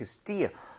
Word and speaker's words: still: 얘는 입모양을 옆으로 이렇게 벌리는 것이 아니라 입모양을 그대로 두고 그냥 still. Still. 그러니까 얼핏 잘못 들으면 약간 스텔인가 싶을 정도로 아.